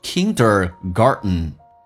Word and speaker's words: kindergarten: 我刚刚有讲过 T 跟 N 组合 T 是可以不发音的所以可以说成 Kindergarten